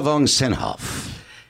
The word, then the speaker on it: Vongsena-Hough: Next, but certainly in the middle of them all, and if I get this right, I've done well, Lela von Senhoff.